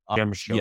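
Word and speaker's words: Przemyśl: Somebody please correct me on the pronunciation that I really don't know Polish, unfortunately. – Shemish. – Yes. There we go.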